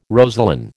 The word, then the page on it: rosaline: American English